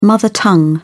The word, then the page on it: mother tongue: British English